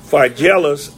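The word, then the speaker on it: Phygelus: Of whom are Philegius and Hermogenes. Philegius and Hermogenes. I had said that, I pronounced that two or three times, but now it's like I'm wondering what it was that she told me about my thermos. Phygellus and Hermogenes, the Lord give mercy unto the house of Onesiphorus, for he oft refreshed me and was not ashamed of my chain.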